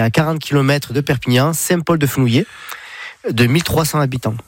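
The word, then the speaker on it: Saint-Paul-de-Fenouillet: Perpignan, vous irez un petit peu plus tard parce que c'est un peu la grande ville pour vous, mais Saint-Paul-de-Fenouillé, c'est comme ça que vous le prononcez.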